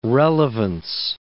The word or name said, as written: relevance